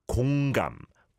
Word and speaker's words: 공감: But the correct pronunciation for today's hashtag empathy, 공감, 공.